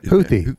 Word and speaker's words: Houthi: Is that Houthi? – Yeah.